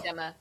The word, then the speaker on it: Gemma: is it Gemma or Gemma? – Gemma. Soft G.